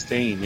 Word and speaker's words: Chastain: We had, of course, the winner for best performance in an actress is Jessica Chastain. Is that how you say it? – Chastain, yeah.